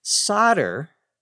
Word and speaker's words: solder: Solder, S-O-L-D-E-R, the L is silent, is a material that you use to connect two pieces of metal together that might be difficult to connect directly using welding.